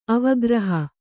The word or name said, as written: ಅವಗ್ರಹ